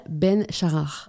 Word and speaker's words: Ben Shahar: Ben Charar.